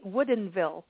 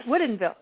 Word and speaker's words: Woodinville: Am I saying this correctly? Woodinville Wine Country. – Yes. Yes. – Woodinville.